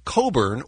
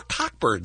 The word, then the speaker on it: Cockburn: Leslie Coburn, or Cockburn, depending on how you choose to pronounce it,